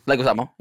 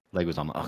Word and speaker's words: Leguizamo: How do you say his name? – Leguizamo, yeah. – Leguizamo, okay.